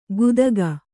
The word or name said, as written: ಗುದಗ